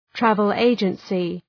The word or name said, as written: travel agency